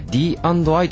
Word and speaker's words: DI: はい、わかりました、株式会社 DI という会社なんですけども、これ実はアルファベットで D&I と書いて DI っていう、呼び方をしています。